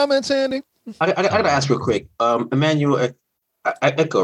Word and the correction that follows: Acho: right? That's how you pronounce his last name? – Acho. – Okay. Emmanuel Acho.